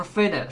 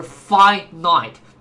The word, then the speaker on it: finite: 然后 finite 千万不要读成 finite，是 finite，表示有限的或者有限度的。